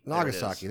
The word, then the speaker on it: Nagasaki: And so the poor cities of Hiroshima and Nagasaki, I might be pronouncing that wrong. – No, that's not. That's not what you. – Hiroshima. – Hiroshima and Nagasaki. – Nagasaki. There we go.